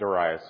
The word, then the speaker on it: Darius: This new ruler in Babylon, the kingdom of Babylon, Darius, which for all my life I said Darius, and I actually made the mistake of looking it up, and they say you should pronounce it Darius, so that's why I'm doing that.